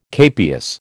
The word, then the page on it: capias: American English